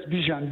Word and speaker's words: Bijan: Yes, Bijan. B-I-J-A-N, yes.